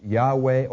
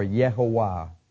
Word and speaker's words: Yahweh: And so people say they're either pronounced Yahweh or Yehovah.